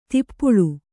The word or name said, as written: ದೀಪಾಳಿ